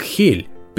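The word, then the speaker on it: Hel: Во-первых, само название города Хель пишется как Хел, почти как англоязычный вариант Hell, ад, только без одной буквы L.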